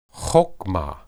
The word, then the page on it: hokmah: Hebrew